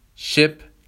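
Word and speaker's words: ship: خب در رابطه با تلفظ دو تا حرف ای کنار همدیگه که بهش میگیم دابل ای، یعنی دو تا ای، تلفظ این دو تا حرف کنار همدیگه ای کشیده هست. ای کشیده هست. به عنوان مثال شیپ، شیپ. منتها شیپ که با آی هست به معنای کشتی، یک چیزی بین ای کشیده و ای کوتاه هست. اما اگر با دوتا ای باشه شیپ به معنای گوسفند هست.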